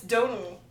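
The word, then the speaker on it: Domhnall: Say his name. – Dom Hall. – No, it's Donal. The Donal? – It's like Donal or.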